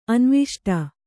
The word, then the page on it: ಅನ್ವಿಷ್ಟ: Kannada